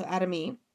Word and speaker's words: Adami: It's spelled with an I, but it's pronounced with an E, so Adami.